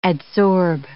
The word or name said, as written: adsorb